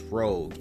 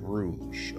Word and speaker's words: Rouge: I recorded this review and I kept pronouncing the title of this album as Rogue and not Rouge, okay? You know, Rogue and Rouge are similarly spelled, so it was easy to mess that up, but I should have known it was called Rouge.